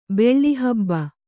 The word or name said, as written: ಬೆಳ್ಳಿಹಬ್ಬ